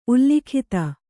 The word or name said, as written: ಉಲ್ಲಿಖಿತ